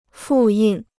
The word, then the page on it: 复印: fù yìn